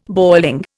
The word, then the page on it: boiling: Indian